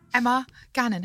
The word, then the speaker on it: Emma Gannon: Emma Gannon, hello.